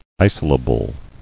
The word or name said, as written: Isolable